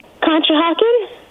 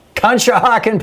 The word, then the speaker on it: Conshohocken: How do you say that town? – Conshohocken. – Conshohocken, Pennsylvania.